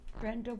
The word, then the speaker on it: Brenda: We are interviewing Brenda Watkinson. – And can you spell that for me, please, Brenda? – Yes, B-R-E-N-D-A W-A-T-K-I-N-S-O-N.